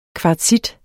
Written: kvartsit

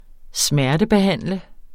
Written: smertebehandle

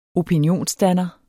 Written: opinionsdanner

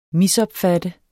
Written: MISOPFATTENDE

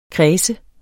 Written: kreds

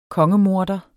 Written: kongemorder

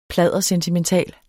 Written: pladdersentimental

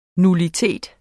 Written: nullitet

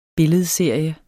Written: BILLEDSERIE

